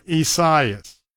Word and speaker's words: Isaias: That's Tropical Storm Isaias. Now, it isn't necessarily spelled the way that it's pronounced. It is I-S-A-I-S, Isaias, and that is a name that originates from Portuguese and Spanish.